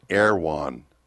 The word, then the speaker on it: Erewhon: It's spelled Erewhon. So we were trying to figure out what the heck is that. They pronounce it Erewhon.